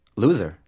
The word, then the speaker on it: Luzer: It's spelled L-U-Z-E-R. – So how did they pronounce it when you were growing up? – Loser. – Oh. – Yeah, it's a Hebrew name that kind of got lost in translation in the past 300 years when Yiddish was invented.